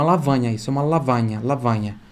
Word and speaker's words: lavagna: E lavagna tem o GN que tem som de NH.